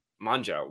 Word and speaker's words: Mongeau: Tana Mongeau.